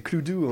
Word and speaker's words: Cluedo: Il faut savoir quand même qu'on est, nous, les franchouillards, on prononce quand même très très mal le nom de ce jeu, parce que c'est Cluedo en anglais. Clue, c'est un indice, tu sais, I don't have a clue.